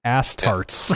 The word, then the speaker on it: Astartes: I'm gonna try to guess how to say this name. Astartes. – Yep. – Astartes. – Yep. Astartes, yeah.